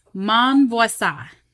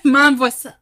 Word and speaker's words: Monvoisin: Mon voisin. So that last syllable just, yeah. Mon voisin. it's not there.